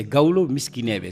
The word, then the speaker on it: Gaolo Miskinebe: Et parmi les 10 titres de cet album, il y a celui-ci, je le prononce peut-être pas très bien, Gualo Minsk Miskinebe. – Voilà, c'est Gaolo Miskinebe.